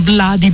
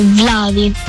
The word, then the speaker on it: Vladi: Vladi. – Vladi, Vladi, Vladi. Datemi una V, una L, una A, una D, una I. Così almeno facciamo prima.